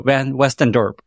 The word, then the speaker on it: Van Westendorp: So Van Westendorp.